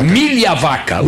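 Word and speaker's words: Migliavacca: É Milhavaca ou Migliavaca? – Milhavaca. O G é mudo, querido.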